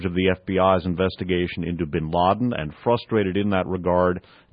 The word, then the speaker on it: bin Laden: I pronounce it bin Laden.